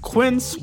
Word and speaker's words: Quince: Today Explained support today comes from Quince, which rhymes with since, but is spelt with a Q-U.